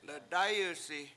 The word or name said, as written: ledAyusi